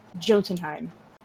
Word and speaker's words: Jotunheim: He's ripped from Asgard to be raised in a world of persistent winter, learning the brutal way of life in Jotunheim. I don't think I pronounced that right. – You did. – I did. Sweet.